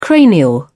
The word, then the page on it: cranial: British English